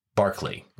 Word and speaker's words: Berkeley: That last name is spelled Berkeley, like the hoity toity Bay Area, California town whose world class university we hope and pray that awkward Jesuit will eventually attend. But the philosopher's name is actually pronounced Barclay, like the round mound of rebound.